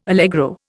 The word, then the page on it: allegro: Indian